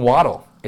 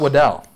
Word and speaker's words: Waddle: It's Jalen Waddle. It's not Waddell,